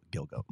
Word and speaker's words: Gilgo: Oh, I thought it was Gigolo. – Gigolo Beach, Gilgo Beach. – I think maybe that's how you say it. – No, it's Gilgo, but the movie itself is based on a book, based on this, you know, this exact same thing, and it's.